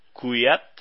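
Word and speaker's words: Kvyat: Red Bull Torroso confirmó a Dani Kuyat, que según un vídeo que he visto de Red Bull se pronuncia así, eh, no sé, Antonio, si, si sabe mejor cómo se pronuncia.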